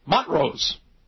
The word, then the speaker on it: Montrose: And it is Montrose. The T is silent. Montrose, Colorado.